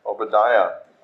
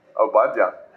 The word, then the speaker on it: Obadiah: Obadiah. How did I say it? Obadiah.